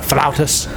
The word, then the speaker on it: flautas: Go down there and I get the crispy, crunchy tacos, the flautas. Flutas or whatever the flutas. – Fuck. – Flautas. – He said it right.